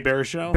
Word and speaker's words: Baruchel: Jay Baruchel? – Baruchel, yeah.